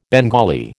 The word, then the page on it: Bengali: American English